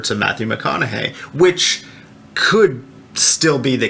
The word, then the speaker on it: McConaughey: But it's possible that Matthew McConaughey, Yep.